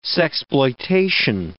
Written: sexploitation

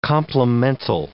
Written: complemental

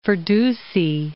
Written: Firdusi

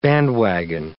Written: bandwagon

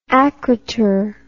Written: akroter

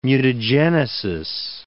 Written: mutagenesis

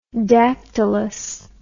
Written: dactylous

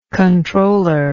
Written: controllership

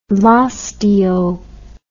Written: laus Deo

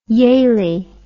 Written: Yalie

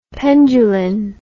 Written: penduline

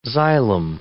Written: xylem

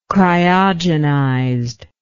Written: cryogenized

